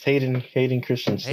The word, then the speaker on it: Hayden Christensen: It's Hayden Christensen.